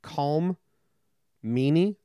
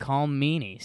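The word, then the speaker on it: Colm Meaney: I don't know how you pronounce it, but if it's pronounced Calm Meany, like a calm, mean person, that's a pretty cool name.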